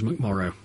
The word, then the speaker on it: McMorrow: rhymes with tomorrow. His name's McMorrow.